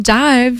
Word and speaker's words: DIIV: And the band Dive, you spell it D-I-I-V, is for fans of bands like Nothing, Slow Dive, My Bloody Valentine, Ride, Soft Cult, and more.